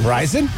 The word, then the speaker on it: Rison: Rison, yes.